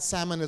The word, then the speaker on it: Salmon: S-A-L-M-O-N. But the L is silent, right? But there's a male name in Israel, S-A-L-M-O-N, where the L is not silent, it's pronounced.